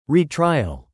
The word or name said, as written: retrial